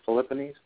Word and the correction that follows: Philippians: How do you say that chapter? – Philippians.